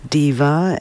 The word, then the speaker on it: Dva: It's spelled D-V-A, but apparently it's Diva. It's Macedonian for the word two.